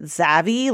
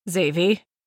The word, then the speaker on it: Xavy: Like, so I have a character named, I say it Zav, like in my head I say it Zavy, like rhymes with savvy, but it's spelled X-A-V-Y. And so some people are like, oh, I've been saying it like Zavy. – Yeah or like, yeah, like rhymes with navy.